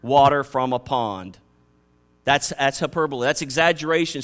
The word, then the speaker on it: hyperbole: The word here for more excellent way is hyperbole, H-Y-P-E-R-B-O-L-E. It's a hard word to pronounce, but I got it down, hyperbole. And it means to exaggerate beyond belief, to say something that nobody believes is so extreme.